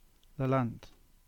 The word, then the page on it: Lelant: English